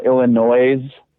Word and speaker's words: Illinois: I've seen posts where it's like Illinois, but the S is silent, and it's like, wow.